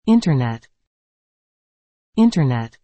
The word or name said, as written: Internet